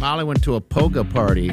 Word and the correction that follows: hygge: It's weird because it's pronounced hooga, but it's spelled H-Y-G-G-E.